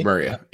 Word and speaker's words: Maria: And now is it Maria or Myra? I never know how to say it. – I think it's Maria. Yeah.